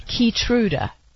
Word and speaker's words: Keytruda: Keytruda, K-E-Y-T-R-U-D-A, formerly known as MK-3475.